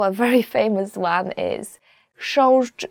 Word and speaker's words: chrząszcz: Chrząszcz, beetle. So the first word is, and I promise you this is the most difficult word you will ever learn in Polish, as for the pronunciation, is chrząszcz.